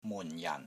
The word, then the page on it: MUN4 JAN4: Cantonese